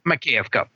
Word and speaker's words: Makiivka: Mark, I want to ask you about this attack on, and help me pronounce it, Makivka. Is that right? Makiivka.